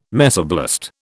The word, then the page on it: mesoblast: British English